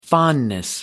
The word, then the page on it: fondness: American English